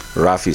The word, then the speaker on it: Ralph: Ralph is spelled as R-A-L-P-H. Ralph, like Raphael.